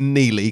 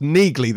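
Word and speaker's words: Neagley: I'm not going to call it Neely because it's pronounced Neegley. There's a G. It's not silent.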